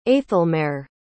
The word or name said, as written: aethelmaere